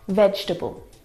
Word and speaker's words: vegetable: Now, this word is a challenge because it looks like there should be 4 syllables in this word. Vegetable. – Vegetable. – But there's not. There are 3 syllables. Veg, we don't pronounce that second syllable.